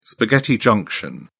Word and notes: spaghetti junction: British English

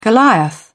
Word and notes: goliath: British English

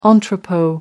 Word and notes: entrepot: American English

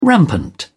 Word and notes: rampant: British English

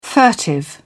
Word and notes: furtive: British English

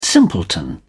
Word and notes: simpleton: British English

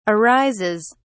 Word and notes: arises: British English

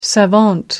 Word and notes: savant: American English

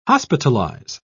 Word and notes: hospitalise: British English

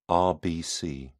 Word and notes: rbc: British English

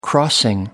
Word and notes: crossing: American English

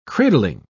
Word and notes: cradling: British English

